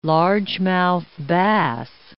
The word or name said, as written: largemouth bass